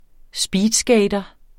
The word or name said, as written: speedskater